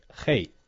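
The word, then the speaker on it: rei (黑): Na última parte do programa de hoje, queremos falar sobre a cor preta na China, cuja pronúncia em mandarim é rei.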